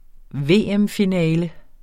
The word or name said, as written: VM-finale